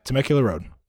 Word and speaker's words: Temecula: I will say I like Temecula Road. Is that how you say it? – Yeah, Temecula Road.